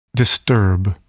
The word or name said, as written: disturb